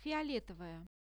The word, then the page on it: fioletovaya: Russian